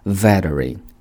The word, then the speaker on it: veteran: veteran 这个单词美式发音读作 veteran。